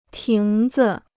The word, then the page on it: 亭子: tíng zi